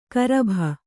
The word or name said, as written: ಕರಭ